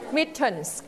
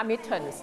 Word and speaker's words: mittens: mittens ออกเสียง s เพราะเราใส่กี่มือเราใส่ 2 มือนะคะ mittens